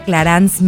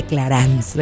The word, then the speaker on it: Clarins: Carla Ortiz, directora general de Clarance México, y Emiliana Rodríguez, Digital and Public Relations Manager de Clarance.